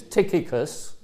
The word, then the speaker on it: Tychicus: So I'm told that the way you pronounce these words that we're going to come across is Tychicus, okay? Generally speaking, if you get CH, like in Christ, it's ch rather than ch.